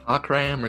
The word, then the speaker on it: Cockram: It might be pronounced Cookram or Cookram, but it's spelled Cockram. C-O-C-K-R-A-M. – Cockram or Cookram? – Cockram.